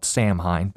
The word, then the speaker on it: Samhain: It's spelled Samhain, but note pronunciation in Irish makes an exception for everything.